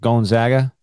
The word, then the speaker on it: Gonzaga: They put a real emphasis on the gon, G-O-N. – Yeah, like Gonzaga.